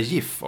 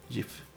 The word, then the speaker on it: GIF: Jag har sakta men säkert börjat säga GIF också. För det är så man ska uttala det.